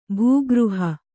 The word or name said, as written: ಭಾಗಾರ್ಹ